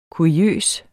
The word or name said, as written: kuriøs